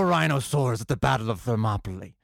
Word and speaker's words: Thermopylae: Is that how you pronounce Thermopylae. – It? – Thermopylae. – Thermopylae. – Yes.